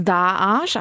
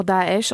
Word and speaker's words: Daesh: Also ähm, man, man kann, eigentlich heißt es Daesh, also wie zwei A, man kann aber auch Daesh oder Daesh sagen, das mhm.